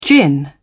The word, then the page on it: gin: British English